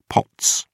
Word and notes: pots: British English

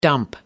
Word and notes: dump: American English

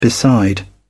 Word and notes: beside: British English

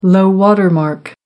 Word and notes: low-water mark: American English

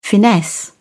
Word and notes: finesse: British English

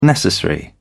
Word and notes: necessary: British English

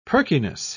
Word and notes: perkiness: British English

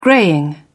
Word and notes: greying: British English

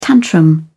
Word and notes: tantrum: British English

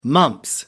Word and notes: mumps: American English

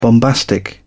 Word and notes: bombastic: British English

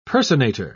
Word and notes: personator: British English

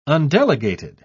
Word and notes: undelegated: British English

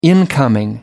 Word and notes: incoming: American English

